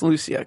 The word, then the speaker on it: Lucia: And I keep wanting to say Lucia, but it's Lucia, correct? – Lucia, okay. Mm-hmm. I'm putting the emphasis on the wrong part there.